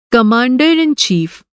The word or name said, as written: कमांडर इन चीफ़